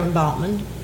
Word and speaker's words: Baughman: Sarah Baughman. – Baughman. I didn't know how to say it. It's spelled B-A-U-G-H-A-M? – B-A-U-G-H-M-A-N.